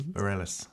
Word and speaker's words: Bareilles: How would you pronounce Sarah's name? – Bareilles. – Bareilles. Bareilles. There we go.